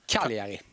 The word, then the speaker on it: Cagliari: Enligt Expressen från Italien, från Cagliari. Det var ingen bra uttal. – Vad säger man då? Cagliari? – Cagliari. – Cagliari, okej. Okay.